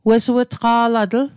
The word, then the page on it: wesuw'tqa'latl: Mi'gmaq